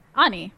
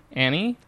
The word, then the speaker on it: Ani: Ani. – Ani. – Annie? – Ani. – Not Annie. – No. – Never Annie. – Never Annie. It's not Annie. It's Ani.